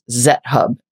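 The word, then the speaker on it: XetHub: Which is a company called, I'm assuming it's pronounced Zethub. It's X-E-T-H-U-B, like GitHub, but Zet.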